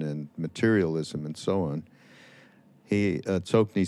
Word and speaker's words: Tsokni: Tsoki Rinpoche is how it's supposed to be pronounced, I think. – Tsoki, you know? – Not Tsoki Nima, no, Tsokni. – Oh, Tsokni. Oh, yeah. – Yeah, Tsokni.